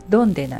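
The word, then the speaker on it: dónde: どこにという意味の単語はドンデなんですね。ドンデは DONDE と書いて O の上にアクセントです。それで君はどこに住んでるのだったら、君は住んでるが to be base で疑問文なので be base to、それに、ドンデをくっつけてドンデ be base to、それで主語が省略できるのでドンデ be base といえばどこに住んでるのと聞くことになります。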